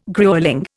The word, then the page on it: gruelling: Indian